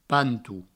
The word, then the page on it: panto: pantou